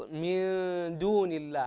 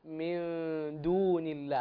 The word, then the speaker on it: من دون الله: انتبه فقط من دون الله هكذا تنطق، هذا حكم الإخفاء هنا، من دون الله هكذا تنطق.